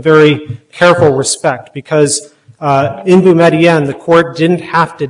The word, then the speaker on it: Boumediene: Yeah, so it was at issue in Boumediene, and I'm going to defend that pronunciation, by the way. When the case first came before us, Judge Randolph and I agreed we would pronounce it. However, the first lawyer pronounced it that got up, and he said Boumediene. So I've stuck with that ever since.